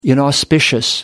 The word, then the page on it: inauspicious: American English